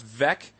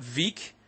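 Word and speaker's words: Veeck: Turns out the owner of the White Sox last name is pronounced Vec, not Veek.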